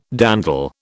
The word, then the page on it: dandle: British English